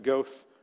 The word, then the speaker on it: Goethe: It was spelled G-O-A-T-H-E. We pronounced it Goethe Road.